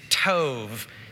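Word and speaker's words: tov (טוב): It's tov, T-O-V, kind of rhymes with stove with a long O.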